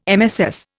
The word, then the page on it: MSS: Indian